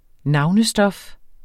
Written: navnestof

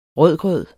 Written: rødgrød

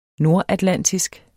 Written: nordatlantisk

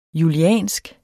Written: juliansk

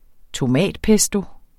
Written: tomatpesto

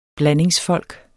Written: blandingsfolk

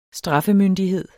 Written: straffemyndighed

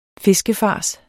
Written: fiskefars

